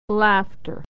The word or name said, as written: laughter